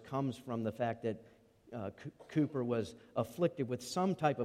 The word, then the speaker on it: Cowper: It's written by the hymn writer William Cowper, looks like Cowper, but pronounced Cooper, who was a brilliant man, but a man who could very much identify with Psalm 42:43.